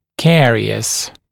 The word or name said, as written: carious